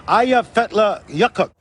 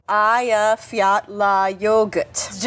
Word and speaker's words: Eyjafjallajökull: The glacier is called Eyjafjallajökull. – Eyjafjallajökull. – Just think of, hey, you forgot your yogurt.